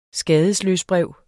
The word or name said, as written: skadesløsbrev